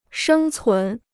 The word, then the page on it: 生存: shēng cún